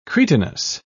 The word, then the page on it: cretinous: British English